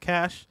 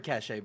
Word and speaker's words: cache: Cash is pronounced Cash. – Yeah. – I've heard cachet before.